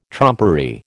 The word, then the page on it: trumpery: American English